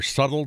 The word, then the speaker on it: subtle: Or subtle differences. – Subtle. The B is silent.